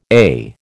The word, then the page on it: eh: American English